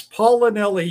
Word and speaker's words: Paolinelli: But how do you say your last name, sir? – It's Paolinelli. You were so close.